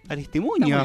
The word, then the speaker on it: Aristimuño: Estábamos escuchando ahí a Aristimundo. Acá somos fan. ¿Lo dije bien? – Aristimuño. Bien. Aristimundo le mandó.